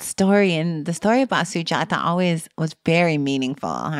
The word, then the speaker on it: Sujata: But you tell a story in there about a important part of the story of the Buddha, Sujata. Am I pronouncing that correctly? – Yes.